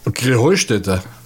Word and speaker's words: Krähenheustätte: Und dabei heißt es doch Krähenheustätte, oder wie? – Krähenheustätte. – Ja, genau, und das die Betonung auf dem Heu.